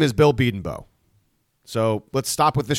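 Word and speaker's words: Bedenbaugh: We're pronouncing his name different now. Britt Venables didn't pronounce his name different. I don't think the head coach would mispronounce his offensive coordinator's / whatever name. So let's put this to bed. This nonsense. – Bill Beedenbow is a much Beedenbow is a much better name than Beedenbaugh.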